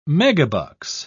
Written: megabucks

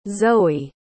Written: zoe

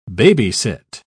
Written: babysit

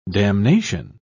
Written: damnation

